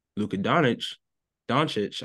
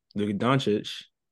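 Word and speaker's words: Luka Dončić: I mean, you got two of the best offensive players in the league, and Kyrie and Luka Doncic, I'm sorry. Dad, I mispronounced names again. Luka Doncic.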